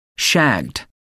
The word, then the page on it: shagged: American English